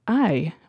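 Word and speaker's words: IJ: However, it's built on the River Eye, which is spelled I-J.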